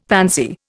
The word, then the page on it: pansy: Indian